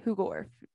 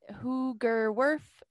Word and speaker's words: Hoogerwerf: My last name is pronounced Hoogorf, just in case you want to know. Becca Hoogorf. – Hoogerwerf? – Hoogerwerf. It is phonetic.